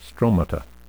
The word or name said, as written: stromata